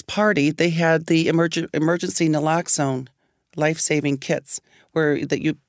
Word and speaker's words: naloxone: Maybe they should not be allowed to release someone from rehab without some naloxone.